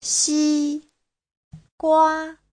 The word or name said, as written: xi1gua1